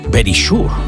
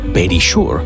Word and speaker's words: Verisure: O Verisure, lo he dicho tal cual, Verisure. Seria la pronunciació correcta. Verisure està com escrit, però és Verisure.